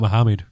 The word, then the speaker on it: Mohammed: Start talking about Mohammed. – Because that'll reduce the listener base, because there's loads of Mohammeds in the UK. – Can we pronounce it correctly, please? It's Mohammed.